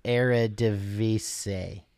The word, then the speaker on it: Eredivisie: Both teams are named after teams in the Dutch league, and the Dutch league is pronounced Eredivisie.